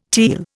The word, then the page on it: teal: Indian